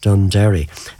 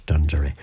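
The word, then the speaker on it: Dunderry: So from now on, it's Dundurry, not Dundurry, as I would have called it over the years. The local pronunciation is always the correct one. So it's Dundurry from now on.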